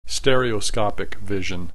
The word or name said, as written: stereoscopic vision